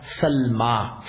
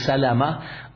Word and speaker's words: Salma: What about Abu Bakr radiallahu, what about Abdul Rahman radiallahu anhu's grandmother, meaning his father's mother, was Salma bintu Sakhr. Salma bintu Sakhr. Salma bintu Sakhr, not Ummu Salama. Salama is a different pronunciation.